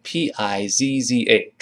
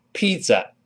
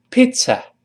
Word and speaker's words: pizza: 后面那个呃，这个意大利馅饼 p i z z a，注意发音，不要把它读成 pizza，不是这么读的，中间是发 t s 那个刺刺，像刺鱼刺的刺那个刺，这个声音声带不震动，跟老师读起来，pizza pizza，哎，这个容易读错，那当然也不能读成 pizza。因为后面没有字母 r，舌尖不要勾。